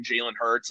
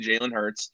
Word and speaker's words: Jalen Hurts: I mean, if you look at Jalen Hurts, and yes, I said that correctly, Jalen Hurts.